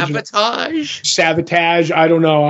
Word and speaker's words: Savatage: S-A-V-A-T-A-G-E, I think. – Okay. – So it's like savage, but sabotage kind of a deal.